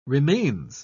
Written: remains

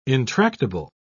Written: intractable

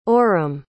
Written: orham

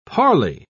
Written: parlay

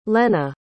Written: lenna

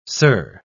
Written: sir

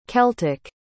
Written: celtic

